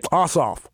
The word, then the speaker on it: Ossoff: But did not John Ossoff from Georgia? – Mm-hmm. – I think that's how you pronounce his name. – Ossoff.